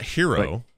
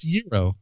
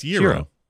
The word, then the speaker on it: gyro: It's not hero, right? – It's gyro. – Yeah, it's gyro. – Y-E-R-O is pronunciation.